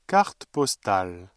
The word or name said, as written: carte postale